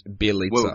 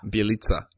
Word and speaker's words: Bielica: his name rhymes with pizza and his name's Bielica. – Whoa. – Bielica.